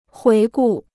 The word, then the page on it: 回顾: huí gù